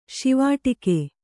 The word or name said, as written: ಶಿವಾಟಿಕೆ